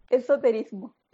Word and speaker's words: esoterismo: ¿Tenemos otro eso, eso Eh, ¿Cómo se dice? – Eso, erotismo. – ¿Cómo es? – Esoterismo. – Esoterismo, gracias.